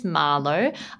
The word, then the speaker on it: Marlo: So, hi, my name is Marlo. A lot of people like to mispronounce it and call me Mario, especially at coffee shops, which is kind of funny because you're in Italy right now.